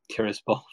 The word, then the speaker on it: Tiraspol: And then the other team that caught my eye is obviously Sheriff Tiraspol from Moldova, who have managed to get through.